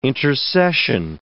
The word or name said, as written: intercession